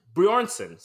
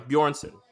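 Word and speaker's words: Björnsson: The pronunciation, by the way, of this man's incredibly super Icelandic name is Hofthor Julius Bryson. Or Thor. – That can't be right. – Bjornsson. Sorry, Bjornsson.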